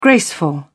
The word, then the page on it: graceful: British English